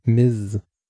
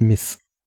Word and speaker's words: Ms: Und es spricht sich, so wie ich es in Erinnerung hatte, mit einem stimmhaften S am Ende. Also Miss. Also nicht zu verwechseln mit Miss.